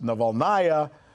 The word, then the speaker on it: Navalnaya: Anyway, they say that when she heard that Mrs. Navalny, pronounced Navalnaya, was also invited, she said, "Well, then I'm not going.